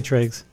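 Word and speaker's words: Tröegs: Then from Troy, how do you say it? Troeg? – Oh, Treg. I just say Tregs.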